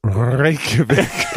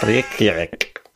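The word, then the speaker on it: Reykjavík: Because you didn't really know how to pronounce Reykjavik. – Reykjavik. – As I answered John's question before he was on the call, no, they don't really roll the R's.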